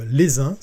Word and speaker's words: Leysin: Je t'invite à taper dans Google Maps euh, les uns, ça s'écrit L-E-Y-S-I-N, voilà, dans, les Alpes vaudoises.